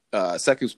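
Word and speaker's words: Sekou: Where is Siku at? – Sure. I think by that point, Seku's playing. First of all, I think by that point, I firmly am pronouncing his name correctly.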